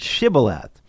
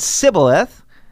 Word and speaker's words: Shibboleth: they would make him say the word Shibboleth. Evidently, Ephraimites couldn't pronounce the SH sound. So if he said Sibboleth, then the Gileadites would kill him on the spot, and 42,000 Ephraimites were put to death there.